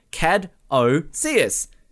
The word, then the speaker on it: caduceus: Let's go through it slowly. Caduceus. I mispronounced it. Say it again. Cad-o-seus. Now quickly. – Cad-o-seus.